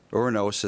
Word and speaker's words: Uranus: Uranus is actually the more accurate pronunciation.